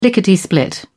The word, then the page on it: lickety split: British English